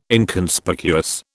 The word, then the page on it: Inconspicuous: British English